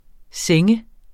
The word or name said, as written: seng